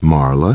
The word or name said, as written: Marla